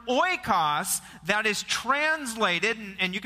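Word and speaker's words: oikos (οἶκος): And it's a Greek word that's pronounced oikos.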